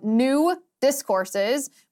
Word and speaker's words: New Discourses: He's also the founder of New Discourses, which you have to pronounce exactly correctly or else it sounds like nudist courses.